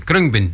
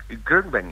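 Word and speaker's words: Khruangbin: En dat het klinkt een beetje als krung. En het wordt ook zo uitgesproken, krungbin. – Ja, krungbin, ja, inderdaad. – En het komt eigenlijk uit de Thais.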